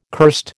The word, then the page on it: curst: American English